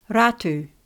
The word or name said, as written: Ratu